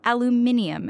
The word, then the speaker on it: aluminium: We talked about Section 232 of the Trade Expansion Act, steel and aluminum imports, or aluminium, as one of our panelists insists it's pronounced, and the World Trade Organization, among many other topics.